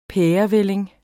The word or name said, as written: pærevælling